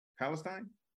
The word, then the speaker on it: Palestine: How do you pronounce it? Palestine or Palestine? Palestine? – Palestine. – Palestine. Okay.